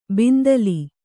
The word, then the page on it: ಬಿಂದಲಿ: bindali